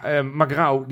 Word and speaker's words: Magrão: Magrão? – Nou, perfect. – Echt waar? – Ja. Gerson Magrão, want zo spreek je het uit.